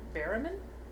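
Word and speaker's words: Berryman: How do you say his name? – What, John Barryman? – Say it slowly. – Barryman? – It's Barryman.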